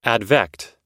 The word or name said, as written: advect